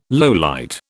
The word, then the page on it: lowlight: British English